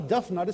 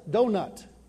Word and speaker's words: donut: It's not a duff nut, it's a donut. But it's spelled just like tough, is it not?